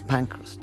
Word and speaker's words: Pankhurst: Pankhurst.